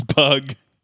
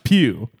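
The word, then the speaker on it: Pugh: So you had Florence Pug. – I think it's pronounced Pew. – Oh, that's why she left me. Oh, Pizone. – Close. Okay, so Florence Pew left you because you thought her name was Florence Pug.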